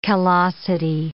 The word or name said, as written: callosity